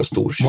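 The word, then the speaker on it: Storkyro: Och centrum för det här upproret, det blir ju Kyrro, eller Storkyrro som han uttalade på finlandssvenska, Storkyrro.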